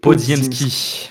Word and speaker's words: Podziemski: même le, le, le, rookie, alors je retrouve juste son nom pour pas l'écorcher, c'est Podziemski.